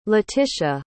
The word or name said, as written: latisha